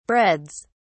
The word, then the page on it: breads: British English